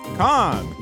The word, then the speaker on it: Khan: Let me pronounce that correctly. Khan.